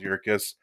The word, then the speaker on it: Yerkes: Am I saying that right? – Yes, Charles Yerkes.